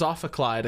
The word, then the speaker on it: ophicleide: I thought it was Ophicleide. – Ophicleide? Well, let's see if Google has any insights. You're right. It is Ophicleide, according to Wikipedia. – Yay. That's 1 point in the pronunciation war. – Excellent. Ophicleide it is.